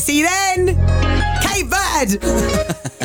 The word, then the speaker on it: Cape Verde: Thank you for joining me this morning and giving the proper pronunciation. So Cape Verde. – Cape Verde.